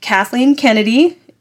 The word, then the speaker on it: Kathleen Kennedy: I do know that we can thank Kathleen Kennedy.